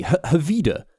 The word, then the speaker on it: Hvide: I mean, that's spelled H-V-I-D-E, Havida, and somehow ends up being I mean, Danish, man. How does it even work?